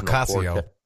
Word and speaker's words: Ocasio: And we were talking before the show about, what's her name, Octavio Cortez? Is that how you pronounce it? – Close enough. – Close enough. Occasional Ocasio. – Ocasio-Cortez.